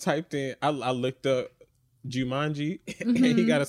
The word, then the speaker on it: Joomanji: It is J-O-O-M-A-N-J-I, Jumanji, and the song is called Where Are You?